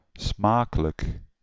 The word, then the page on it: smakelijk: /ˈsmɑkələk/